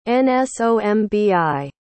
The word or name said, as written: nsombi